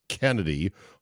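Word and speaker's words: Chennedy: Well, because of Kennedy Carter, who I didn't know the Chenity was actually pronounced Kennedy, hard K sound, and her total cheap shot of Caitlin Clark, which I'm shocked the WNBA has done nothing.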